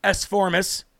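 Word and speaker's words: Esformes: Ladies and gentlemen, Philip S. Forms, that's E-S-F-O-R-M-E-S, S. Forms, or I should say S. Formis. It's probably a Latino pronunciation.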